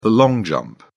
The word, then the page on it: long jump: British English